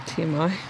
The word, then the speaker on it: TMI: Wait, how do you say it? TMI? T-I-M? – TMI. – TMI.